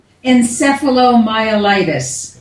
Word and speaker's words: encephalomyelitis: Is that right? – Yes, it's almost right. It's close enough. Encephalomyelitis. – What that encephalomyelitis, is, yeah.